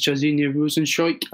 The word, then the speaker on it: Jairzinho Rozenstruik: I believe that's his Junior dos Santos versus Jairzinho Rozenstruik.